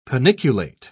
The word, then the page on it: paniculate: British English